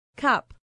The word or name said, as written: cup